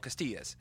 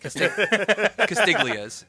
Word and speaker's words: Castiglia's: It's called Castilla's. Castilla's is, or as the locals around here call it, because they can't pronounce Italian words, Castiglia's, because they pronounce the G.